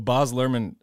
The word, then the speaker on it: Baz Luhrmann: Well, Baz Luhrmann, however you pronounce his name, the guy who directed the movie. – We said it right. – We said it right? – Yeah, whichever way it was. – Oh, okay. That was right.